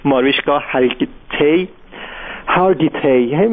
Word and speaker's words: ماریشکا هارگیتی: خب خیلی اون کسی که براش توی مجله تایم مطلب رو نوشته ماریشکا هری اسمش رو نمیتونم خوب تلفظ کنم، اسم بگیر همین هنرپیشه معروف آمریکایی ماریشکا هارگیتی، اسمش اینجوری تلفظ میشه.